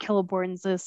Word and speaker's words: Celeborn: You should take a seat at Celeborn's side. – Okay. – In the style of Frodo. Please, yes. So that's how we say his name, with the hard C? – Yes.